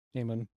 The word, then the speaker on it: Eamon: You can go French, you can go Irish, you can maybe even go German. I think that spelling is German, so. Eamon. – So how do you say it? – Eamon.